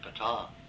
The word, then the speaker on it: Pétain: It's spelled P-E-T-A-I-N, but it's pronounced Pétain.